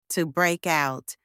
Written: to break out

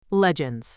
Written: legends

